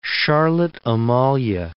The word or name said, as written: Charlotte Amalie